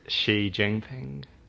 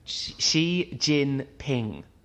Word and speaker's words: Xi Jinping: Xi Jinping. – Xi Jinping.